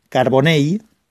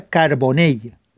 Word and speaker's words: Carbonell: Por tanto, Carbonell se pronuncia Carbonell y Sabadell se pronuncia Sabadell, acabado en elle, como en una EI e si queréis, Sabadell, Carbonell eh, y demás.